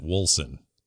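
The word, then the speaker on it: Wolcen: I have heard the people who make the game pronounce it Wolsen, which sounds like Wilson and is a problem.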